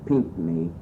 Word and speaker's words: Pinckney: Okay, the spelling is P-I-N-C-K-N-E-Y. Many times it's spelled, I think, even out at Pioneer Village, P-I-N-K-N-E-Y, so the C was left out.